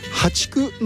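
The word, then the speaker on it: 淡竹: でこれからはね、ハチクっていうね、淡い竹と書いてね、淡いはあの淡水魚の淡ですね、淡い竹と書いてハチク、そして、今月末にはマダケといった種類が芽を出してくるということなんですね。ハチクの方のタケノコですが、孟宗竹よりも細長くて、アクやえぐみ、苦みが少なく、繊細で柔らかく甘みがあって食べやすいと言われています。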